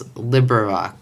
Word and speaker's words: LibriVox: They pronounce it in the recording. It's LibriVox.